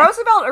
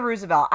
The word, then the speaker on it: Roosevelt: Is it Roosevelt or Roosevelt? I fucking mispronounce everything, I need to know definitively. – I think that amongst themselves they say Roosevelt, but I don't think they'd correct anyone for saying Roosevelt.